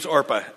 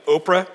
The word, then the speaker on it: Orpah: Her name's Orpah. And I'm always tempted to pronounce it Oprah, because it's really close to the same spelling, but it's Orpah.